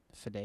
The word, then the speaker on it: Fede: Fede, is that how you say his name? – It's Fede. It's probably F-E-D-E.